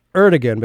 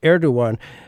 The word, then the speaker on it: Erdogan: It looks like Erdogan, but it's I guess pronounced Erdogan.